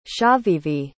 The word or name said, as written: chavivi